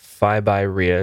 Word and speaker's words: FIbyREI: Your website is Fibyrea. Is that how you pronounce that? – Yeah. – So that's Fibyrei, and that stands for Financial Independence by Real Estate Investing.